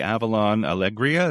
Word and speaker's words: Avalon Alegria: And on board the Avalon Alegria, is that how you pronounce it? – Yes.